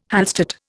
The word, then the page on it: Hallstatt: Indian